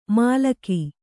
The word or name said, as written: ಮಲಕು